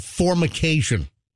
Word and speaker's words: formication: And Roxanne looked it up, and it's called formication. After a misidentification of fornication at first. – She said it correctly. I think we just misheard it. – I think I heard fornication.